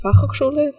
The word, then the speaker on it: Fachhochschule: It's the Fachhochschule in Potsdam.